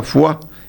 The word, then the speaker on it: Foix: Y como buen descendiente de franceses, porque el apellido Foix se pronuncia en realidad Foix, eran franceses, claro.